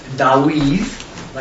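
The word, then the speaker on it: Dawid (דוד): But what we're going to look at is David, and actually how you pronounce his name is Dawid, like W, or D-A-W-E-A-T-H, Dawid. And Saul is Shaul, like S-H-A-U-A-L, or something like that.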